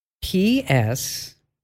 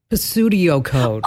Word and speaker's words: pseudocode: PSEUDOCODE. So PSEUDOCODE. – Pseudocode? – Yes, pseudocode. But some people, I've heard them pronounce it like Pseudio code. – Oh, PS is never PS. Yeah, never, ever, ever.